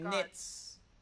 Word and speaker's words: Nitz: I'm Nitz.